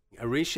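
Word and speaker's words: Arís: Arish is spelt, first of all, A-R-I-S, and there's an accent on the I.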